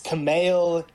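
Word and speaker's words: Kumail: His name's not even Kamal. – Is it Camille? – It's Camille.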